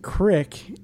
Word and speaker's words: creek: Well, first of all, I think you guys are mispronouncing it. They pronounce it "crick" in the documentary.